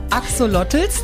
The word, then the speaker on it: Axolotls: Wir hatten heute schon von Alpakas äh, gehört, von Ratten und Spinnen und äh, jetzt gibt's also auch noch Axolotls. Habe ich es richtig ausgesprochen? ja, Axolotls, das sind äh, mexikanische Lurche.